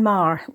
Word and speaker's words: Marr: Hello, I'm Jane Marr. Yeah, that's the way you pronounce my surname. It's Irish.